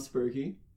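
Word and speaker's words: Sperkie: Spurky.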